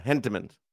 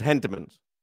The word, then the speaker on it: Hentemans: I've only isn't there an H? – Yeah, Hentemans. – Okay, it just sounded like you were saying an E with a silent H. – No, it's Hentemans.